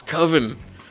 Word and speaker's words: Coven: When he's like, it's Coven. Isn't it pronounced Coven? He's like, Coven, man. That sounds like oven. I don't want to have a horror movie. It sounds like oven. That's not scary. It's Coven.